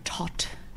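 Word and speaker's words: Toht: And then we have, I guess, our even worse villain, Major Tot. – Yes. – Right? That's how you say it? – Yeah.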